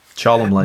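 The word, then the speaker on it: Cholmondeley: And that's why you have people like, I mean, especially like the individual Charles Chumley, even though it's spelled with, he has like, what, 19 different extra letters in the spelling. – Yeah, I know, right? Chumley.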